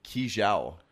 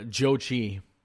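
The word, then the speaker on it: Zhou Qi: That's Joe Chi. Joe Chi is his name. That's how it's pronounced. That's the center prospect from the Rockets. So they got him a one-year min deal, basically. But yeah, no, it looks like Zhao Chi because it's backwards there, but I apparently it's pronounced Joe Chi. Joe Chi is how that's actually pronounced.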